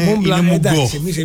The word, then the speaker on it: Mont Blanc: Mont Blanc, το σε δεν προφέρεται. Είναι Mont Blanc. – Εμείς οι βλάχοι τώρα Mont Blanc τη λέγαμε.